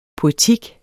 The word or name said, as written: poetik